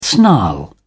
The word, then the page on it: snarl: British English